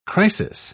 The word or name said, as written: crisis